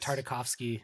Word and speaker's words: Tartakovsky: the Gendy Tarkady or yes. – Tartakovsky. – Tartakovsky. I never pronounce it right.